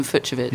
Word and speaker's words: Fucsovics: I went to lengths to ascertain how to correctly pronounce his name. So there you go, Martin Fucsovics.